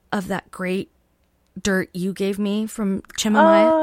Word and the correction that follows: Chimayo: Did I say it correctly? – Chimayo? – Chimayo.